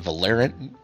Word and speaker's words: Valorant: Valorant. – Valorant. Yeah, it's Valorant. Valorant. It's literally the word valor.